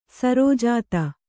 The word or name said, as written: ಸರೋಜಾತ